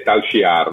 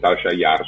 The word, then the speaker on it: Tal Shiar: Anche in lingua inglese la pronuncia è Tal Shiar, non Tal Shiar. Sono io che la pronuncio Tal Shiar.